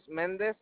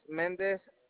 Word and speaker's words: Mendez: My first name, Luis, last name, Mendez. – Can you spell that for me? – Luis, L-U-I-S, Mendez. – L-U-I-S? – Yes. My last name is Mendez. Mendez, it's M-E-N-D-E-Z. – M-E-N-D.